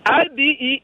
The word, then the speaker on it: Ibeawuchi: IBEAWUCHI.